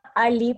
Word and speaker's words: A11y: Eh, bueno, la comunidad se llama Ali Perú y se escribe A11Y. Y esta, esta palabra Ali en general es eh, una expresión mundial para referirse a accesibilidad.